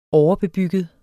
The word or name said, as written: overbebygget